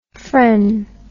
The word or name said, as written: phren